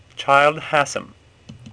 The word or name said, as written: Hassam